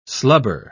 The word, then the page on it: slubber: British English